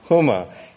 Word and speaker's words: Homa: The word Homa is actually pronounced Huma.